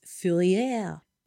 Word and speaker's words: Fourier: And a lot of those communities were inspired by a French socialist called Charles, of course, it's spelled Fourier, but I'm sure it's Fourier.